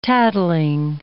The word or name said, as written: tattling